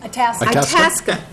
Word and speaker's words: Itasca: How do I say that? Itasca County? – Itasca. – Thank you. Help me. I'm not from Minnesota. Itasca.